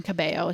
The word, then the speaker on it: Cabello: So we've been watching, and Camila, Cabello? – Yes. Cabello is how you say her last name, but whatever.